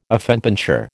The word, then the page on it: affenpinscher: American English